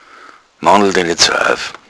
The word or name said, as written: aufmandeln